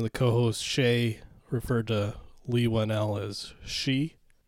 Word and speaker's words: Leigh Whannell: I think it's Leigh Whannell. – Leigh Whannell, is it? – That's how I would expect it to be pronounced.